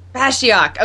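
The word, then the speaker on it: Bashiok: but Bashyak? Bashyak. Okay, that's what I thought, but I wasn't sure.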